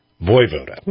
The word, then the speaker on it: Voivoda: Am I pronouncing your last name correctly of Vovoda? – Voivoda. – Voivoda. – Voivoda. Huh? – We weren't sure what to do with the J.